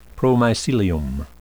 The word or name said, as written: promycelium